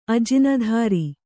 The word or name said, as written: ಅಜಿನಧಾರಿ